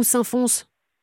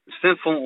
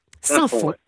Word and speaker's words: Saint-Fons: Alors, comment on dit David Saint-Fond ou saint fonce Saint-Fond, on prononce pas le S. – Très bien. – Saint-Fond, voilà.